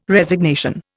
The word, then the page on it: Resignation: Indian